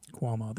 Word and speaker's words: Cuomo: Cuomo. – Cuomo. Quo. Like quote me this. – Okay. I'll try next time. – Rhymes with slow-mo. – Yeah. Cuomo. – Cuomo.